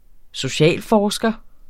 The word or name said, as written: socialforsker